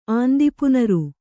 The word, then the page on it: ಆಂದಿಪುನಾರು: āndipunāru